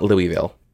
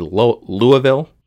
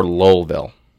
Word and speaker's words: Louisville: It's not Louisville. I mean, yes, that's how it's spelled, and that's how it would technically be pronounced, but it's either two things. It's either Louisville, the E kind of just drops off, or Lowellville. – Lowellville.